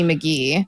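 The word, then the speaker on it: Magie: McGee Phillips, or Lizzie McGee. I could be mispronouncing her last name. It's M-A-G-I-E, but I think that's pronounced McGee.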